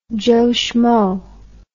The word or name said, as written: joe shmo